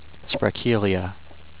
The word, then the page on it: Sprekelia: sprek-KEEL-ee-uh